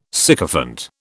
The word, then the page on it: sycophant: British English